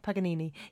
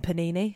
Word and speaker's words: Paganini: Have I said that right? Paganini. Yeah. – Yeah. – When I was reading it earlier, I kept saying Panini, so that's not... – I mean, it's written similar.